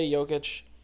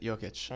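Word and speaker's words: Jokić: We got LeBron, Kevin Durant, Paul George, Steph Curry, James Harden, Nikolai Jokic. How do you say his name? – You got it. Jokic.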